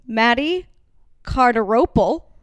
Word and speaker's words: Matty Cardarople: There's pressure for me to get your name right, right in front of you, Matty Cardarople.